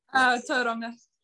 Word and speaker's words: Tauranga: Is it Tauranga? – Yeah. – Yeah, is that correct? Or have I... – Tauranga. – Tauranga. Right.